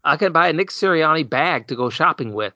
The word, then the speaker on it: Sirianni: Um, and that's, that's kind of well, I, I think Serenini, is that how you say it? Nick Seren, Serenini? Uh, uh, Serini? Siri- Oh, Sirianni. – Sirianni. Sir- oh, Sirianni. Thank you.